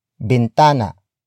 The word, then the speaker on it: bintana: And that is bintana. Bintana. Bintana. Bintana is spelled as B-I-N-T-A-N-A.